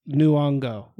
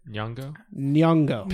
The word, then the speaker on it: Nyong'o: I would sell Peele by Luongo. – Oh, okay. – Nyong'o. – Okay. How do you say it? – Nyong'o.